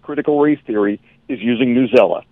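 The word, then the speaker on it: Newsela: Newzella. Am I saying that correctly? – That's exactly right.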